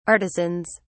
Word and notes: artisans: British English

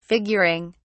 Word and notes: figuring: British English

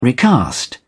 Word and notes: recast: British English

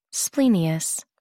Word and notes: splenius: American English

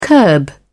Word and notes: curb: British English

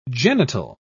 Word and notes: genital: British English

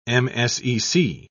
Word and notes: msec: British English

